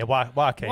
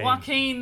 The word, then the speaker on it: Joaquin: Joaquin? How do you say his name? – Yeah, Joaquin. – Joaquin, that's right.